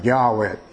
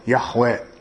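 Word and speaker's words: ヤハウェ: ここで主と太字で書いてあるのは、発音はおそらくヤーウェ、ヤホエという発音であろうと今では言われていますが、あってあるお方の名によって祈ることを始めた。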